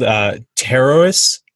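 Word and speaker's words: Pterois: it's called Pteroas is actually the name of it. I had to look up the pronunciation because it's spelled Pteroois, mm-hmm. Which looks like Pretois.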